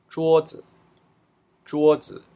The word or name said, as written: zhuozi